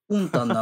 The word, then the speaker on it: 温暖: って、浅野先生オンタンって言ってるって、オンタンなのにと思って。 – 温暖低気圧のその、なんていうのかな。そう。イントネーションが後ろの方なんだね。